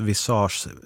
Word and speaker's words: visage: PS, visage uttalas visage, ansikte på franska.